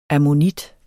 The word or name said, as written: ammonit